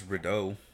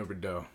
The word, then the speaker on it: Rideaux: How do you say his last name? – I think it's Rideau. – Bino Rideau.